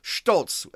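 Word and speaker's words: Stolz: the bizarre Inquisition hearings against the courageous Sylvia Stoltz." I'm going to pronounce it in German. Stoltz.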